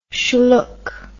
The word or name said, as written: shlook